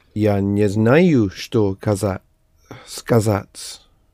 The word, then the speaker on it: сказать: Now let us do the verb to say or to tell, the same, is сказать, sounds like scars, which you inflict on a person if you say something rude to him, yes? Scars, сказать, to say.